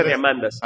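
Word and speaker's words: Diamandis: Por ejemplo, les recomendé a Chichi Atavo que leyeran Bold, de Peter Diamandas. Bold de Peter Diamandas. No, se pronuncia Diamandas.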